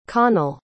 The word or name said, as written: connal